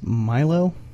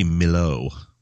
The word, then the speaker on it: Milow: I think it's Milo. – How you spell it? – M-I-L-O-W.